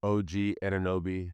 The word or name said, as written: og ANUNOBY